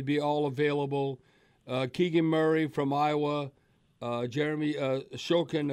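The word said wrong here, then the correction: Sochan: if I'm pronouncing it Sohan. – Right. Sohan from Baylor.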